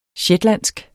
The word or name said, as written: shetlandsk